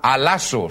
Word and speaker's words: allasso: Ahora esa palabra mudados, amigos míos, en el idioma griego es la palabra alazo. Se escribe, si quieren ustedes escribir y tomar nota de esto, se escribe A-L-L-A-S-S-O. Alazo. A-L-L-A-S-S-O.